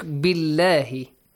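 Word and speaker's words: بالله: The same as this word. Look, billahi. B has a kasra.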